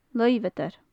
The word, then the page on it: luibheadair: gaelic